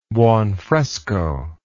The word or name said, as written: buonfresco